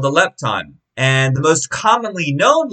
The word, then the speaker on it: lepton: The Greek word for this mite is a lepton, or a lepton if you're trying to pronounce it accurately to the Greek.